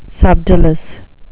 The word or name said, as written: subdolous